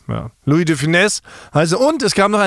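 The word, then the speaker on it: Louis de Funès: Louis Define wird Louis Definesse ausgesprochen, scheinbar. Das S spricht man mit.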